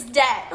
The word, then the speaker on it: debt: And they're like, it's debt. I'm like, the fuck? Does this look like debt to you? – Like, why is there a B in there if it's not even pronounced? – I'm like, why the B here?